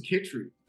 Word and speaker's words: Chytry: So, my last name is Chytry. – Chytry. Okay. – Czech last name. – Okay. – And, I normally put the phonetics. – Well, I pulled something off the internet, and it says pronounced K-I-H-tree.